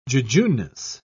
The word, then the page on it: jejuneness: British English